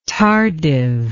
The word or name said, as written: tardive